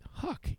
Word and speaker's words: Huc: The enzyme in question is called HUC, pronounced HUC, H-U-C-K, but it's HUC.